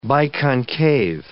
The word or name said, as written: biconcave